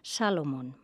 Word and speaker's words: Salomon: que s'havia casat amb Lea Salomon. Salomon, vaja, millor pronunciat Salomon,